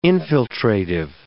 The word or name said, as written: infiltrative